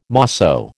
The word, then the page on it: mosso: American English